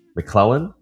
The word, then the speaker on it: McLellan: Today we have Sam McClellan.